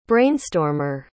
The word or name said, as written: brainstormer